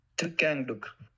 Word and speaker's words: Thich Quang Duc: I'm going to pronounce it Thich Quang Duc. – I will insert the pronunciation right now. – Thich Quang Duc.